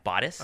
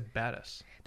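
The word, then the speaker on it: Batis: Is that how you say it? – Yeah, Bodice. – I always say Badass. – They told me Bodice.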